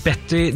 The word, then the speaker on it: Bette: Det som er det morsomt er at hun sier navnet sitt som Betty Davis, mens Bette Midler er oppkalt etter Betty Davis. Det skrives likt, men uttales forskjellig.